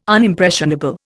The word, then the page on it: Unimpressionable: Indian